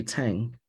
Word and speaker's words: Tsang: So I just casually say, well, Henry Tsang, you know, that's how I've always pronounced my name for the last 40 years. That's how school taught me to say my name, because this is how it's spelled in English.